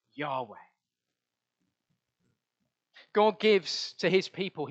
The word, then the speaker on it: Yahweh: In Hebrew, it's just four letters, YHWH, probably pronounced Yahweh.